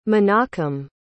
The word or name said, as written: Menachem